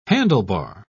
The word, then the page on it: handlebar: British English